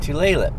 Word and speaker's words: Tulalip: At an outlet mall that I didn't know existed in a town called Tulalip. – Yes, Tulalip. – Tulalip.